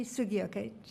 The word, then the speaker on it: Sugioka: And so then I'm going to move right on to item 11A, and that is my reappointment of our poet laureate, Kimi, is it Sugioka? The hard G, Sugioka.